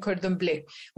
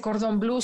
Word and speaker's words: Cordon Bleu: Por ejemplo, mi hija está estudiando para chef en Cordon Bleu o Blue, ¿no? Ay, mi amor, no se dice cordón blue, se dice cordon bleu, no seas naco.